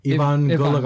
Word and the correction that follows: Evonne Goolagong: Evonne Goolagong did it.